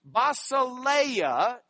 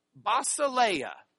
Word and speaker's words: Basileia: Basileia is the way we would pronounce this. Basileia. Say it with me. Basileia.